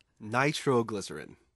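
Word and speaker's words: nitroglycerin: 노벨상을 만든 앨프레드 노벨은 발명가 겸 사업가로 위험물질인 니트로글리세린, 발음 어떻게 되나요, 영어로? – 나이트로글리세린. – 나이트로글리세린.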